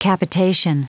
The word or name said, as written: capitation